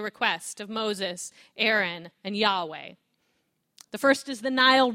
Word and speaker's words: Yahweh: But the God who calls themselves, I am who I am, pronounced something like Yahweh, will not take no for an answer.